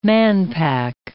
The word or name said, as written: manpack